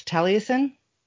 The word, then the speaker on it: Taliesin: It's Taliesin. God damn it! I don't want people in Wisconsin to hate me. You Taliesin.